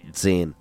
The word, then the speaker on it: Zehn: And then 9 is N-I-E-N, which is no. – What's yeah. What's 10? – Zehn. – Zehn? – Zehn. Harder on the Z. It's a Zehn.